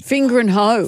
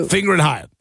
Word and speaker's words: Fingringhoe: F-I-N-G-I-O. – Start again. – F-I-N-G-R-I-N-G-H-O-E. – Finger and hoe. – Finger and hoe.